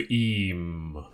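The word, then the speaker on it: Eyam: So how is I am pronounced, quote-unquote, correctly by the people in the Derbyshire village? – It is, of course, the plague village of Eam.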